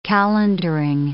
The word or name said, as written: calendaring